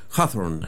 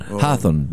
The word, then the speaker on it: Hawthorne: Tú vas a pronunciar mejor el apellido que yo. Nathaniel Hawthorne, ¿no? – Sí, creo que es Hawthorne. – Hawthorne, ya.